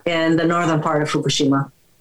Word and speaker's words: Fukushima: Do you pronounce it Fukushima or Fukushima? – No, Fukushima.